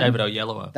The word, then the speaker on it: David Oyelowo: David Oyelowo.